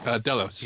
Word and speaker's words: Delos: Like we see the head of how's it pronounced? Delios or Delos? Does anyone know? – Delos. – Yeah, Delos.